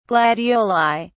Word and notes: gladioli: British English

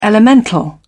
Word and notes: elemental: British English

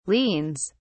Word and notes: liens: British English

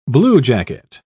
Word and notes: bluejacket: British English

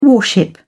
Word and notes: warship: British English

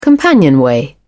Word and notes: companionway: British English

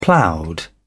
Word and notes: ploughed: British English

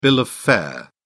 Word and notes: bill of fare: British English

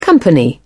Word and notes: company: British English

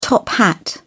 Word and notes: top-hat: British English

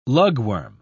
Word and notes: lugworm: British English

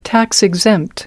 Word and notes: tax-exempt: American English

